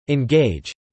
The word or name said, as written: engage